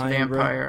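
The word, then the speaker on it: Vampyr: Is it vampire or vampire or I think it's just vampire, honestly. – It's just vampire. – It's a silent Y.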